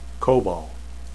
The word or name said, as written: kobal